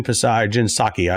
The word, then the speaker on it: Psaki: Do you also think that, I thought about this last week, Jen Psaki, I always want to pronounce the P in her name, but Jen Psaki was asked, do we have a crime problem in this country?